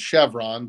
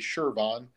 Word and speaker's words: Chervon: So Shervon is our parent company. They bought Flex about 10 years ago. And if you don't know what Shervon is, everybody calls it Chevron, but it's actually Shervon.